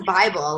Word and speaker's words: Bybel: Our last name is Bible, like the Bible, but it's spelled differently.